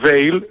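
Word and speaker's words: Weil: Όταν σας λέω ότι ειδικά τις πρώτες μέρες, αν δεν το σκεφτείς, βέβαια στην άλλη περίπτωση που ανέφερα την νόσο του Veil ή Vile, όπως θέλετε να την προφέρετε,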